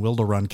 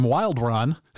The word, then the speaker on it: Wilderun: when Wilderun came on, and I said, and we've got Evan from Wildrun.